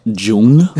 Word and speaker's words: Jung: I believe it's pronounced Jung. Okay.